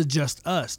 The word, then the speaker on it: Justus: Okay, so there's Just Ice, like spell it Justice, J-U-S-T-I-C-E, Justice, J-U-S-T-I-C-E. And then there's the Just Us, J-U-S-T-U-S.